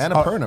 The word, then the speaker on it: Annapurna: Am I saying it right? You seem like the guy who correct me on this. – Annapurna, oh, right? Annapurna.